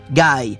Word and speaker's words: Guy: Alors, Guy, ça s'écrit comme Guy de Maupassant, mais ça se prononce Guy, comme Guy Ritchie.